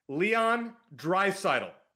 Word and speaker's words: Leon Draisaitl: Say his name again. – Leon Draisaitl. – I don't even know if that's correct. I really it is. No, it is.